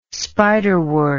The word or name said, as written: spiderwort